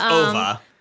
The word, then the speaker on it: Ove: It's a Swedish pronunciation, obviously, of a Swedish name that I'm not familiar with. It's spelled Ove, but that is not right. How you say it, even though that's how I said it in my head the whole time.